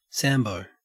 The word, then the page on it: sambo: Australian English